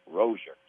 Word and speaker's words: Rozier: It looks to me like Rozier, but he has said that it's Malik Rozier. So Rozier it is.